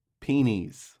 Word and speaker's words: penes: Now, according to this definition, the proper plural is penis. – Ah, oh, that's so much better than How are you spelling that? – P-E-N-E-S. – Wow. – Penis. – Yeah, I had to Google pronunciation.